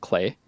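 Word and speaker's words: Klei: I think Don't Starve was probably one of the developer is called Clay. Rhymes with play is the way they put it.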